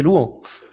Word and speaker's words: Luan: Ah bah oui, mais c'est la prononciation brésilienne, c'est Luan.